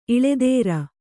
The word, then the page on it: ಇಳೆದೇರ: kannada